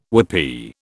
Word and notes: whoopee: British English

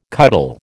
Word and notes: cuttle: American English

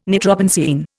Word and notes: nitrobenzene: Indian

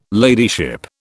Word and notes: ladyship: British English

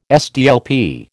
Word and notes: SDLP: American English

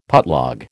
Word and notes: putlog: American English

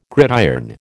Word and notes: gridiron: American English

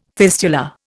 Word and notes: fistula: Indian

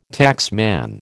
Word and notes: taxman: American English

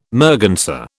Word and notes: merganser: British English

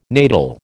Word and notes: natal: American English

